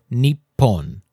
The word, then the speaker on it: Nippon: Thus, we have a kanji compound meaning the sun's origin, which in Japanese is pronounced Nihon or Nippon.